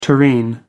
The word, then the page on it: tureen: American English